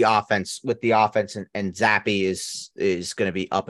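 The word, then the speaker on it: Zappe: And I learned how to pronounce Bailey Zappi's name. – Bailey Zappi. – I always thought it was Zapp. – Nope. Zappi.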